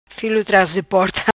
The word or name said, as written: Filo Tras di Porta